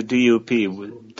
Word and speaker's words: DUP: Yes, it's the DUP, Democratic Unionist Party.